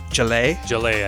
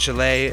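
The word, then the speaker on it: Gelaye: It's G-E-L-A-Y-E. Gelee, I think, yeah. – The song is called Gelee, and the band is Radio Tehran.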